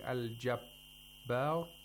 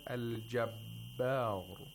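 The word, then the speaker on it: Al-Jabbar (الجبار): In the next verse, when he pronounced Al-Jabbar, the correct way of pronouncing Ba with Tashdeed is that we should not hear a complete sect. If you say Al-Jabbar, that's incorrect. The way Sheikh Minshawi applied the tashdid, he said Al-Jabbar. He did not, you know, cut the airflow.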